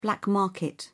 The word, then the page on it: black market: British English